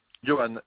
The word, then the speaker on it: Yoan: Ça s'écrit V-I-R-G-I-L-I-O. – Et le prénom? – Johan, Y-O-A-N.